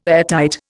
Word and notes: airtight: Indian

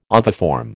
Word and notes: oviform: American English

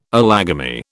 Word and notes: allogamy: British English

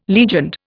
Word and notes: legioned: Indian

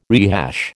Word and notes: rehash: American English

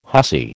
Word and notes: hussy: American English

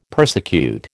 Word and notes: persecute: American English

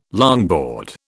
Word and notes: longboard: British English

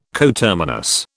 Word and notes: coterminous: British English

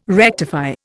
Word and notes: rectify: Indian

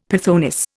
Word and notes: pythoness: Indian

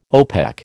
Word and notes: OPEC: American English

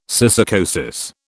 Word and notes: syssarcosis: British English

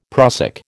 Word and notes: prussic: American English

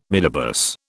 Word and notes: midibus: British English

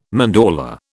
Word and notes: mandorla: British English

